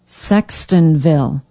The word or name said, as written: Sextonville